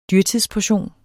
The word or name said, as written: dyrtidsportion